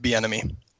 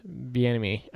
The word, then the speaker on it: Bieniemy: Eric Bieniemy. – Okay. Oh, Bieniemy, yeah. – Bieniemy.